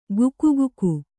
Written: ಗುಕುಗುಕು

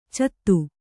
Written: ಚಾತ

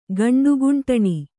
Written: ಗಂಡುಗುಂಟಣಿ

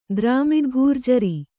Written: ದ್ರಾಮಿಡಘೂರ್ಜರಿ

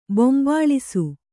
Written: ಬೊಂಬಾಳಿಸು

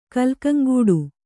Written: ಕಲ್ಕಂಗೂಡು

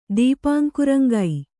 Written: ದೀಪಾಂಕುರಂಗೈ

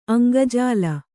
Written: ಅಂಗಜಾಲ

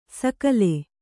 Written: ಸಕಲೆ

